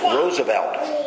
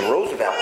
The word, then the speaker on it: Roosevelt: I read in Sunday's paper, it's pronounced Roosevelt because it's a Dutch word, okay, name of a Dutch background. And the R-O-O-S-E means rose in Dutch. And so the actual pronunciation is Roosevelt.